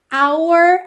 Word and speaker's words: our: Esse our, aí, nosso, nossa, frequentemente também vai ser pronunciado como se fosse a letra R. Our way, ok? R, é a letra R em inglês, certo?